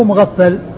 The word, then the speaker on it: مغفل: شخص واحد هو مغفل وهو والد عبد الله الصحابي, الصحابي عبد الله بن مغفل والده مغفل بالغين والفاء المشددة.